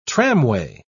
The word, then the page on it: tramway: British English